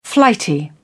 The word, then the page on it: flighty: British English